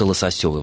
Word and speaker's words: лососёвый: И, соответственно, мы могли сказать лососевый и лососевый. Да, но вот словари, насколько я увидел в словаре русского языка и ударений Ирины Леонидовны Резниченко, только лососёвый, возможно, форма.